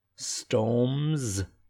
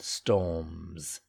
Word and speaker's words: storms: Hurricanes, storms, and you got that z sound at the end, storms, all kinds, again that z sound, all kinds of storms.